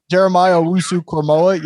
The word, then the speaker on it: Jeremiah Owusu-Koramoah: Asamu. Say it for me, please. – Jeremiah Owusu-Koramoah.